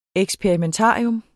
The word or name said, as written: eksperimentarium